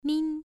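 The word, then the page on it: min: tone 1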